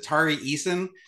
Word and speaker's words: Tari Eason: Is it Tari Eason? Am I saying that correctly? – Yeah.